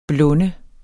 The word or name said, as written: BLUNDEDES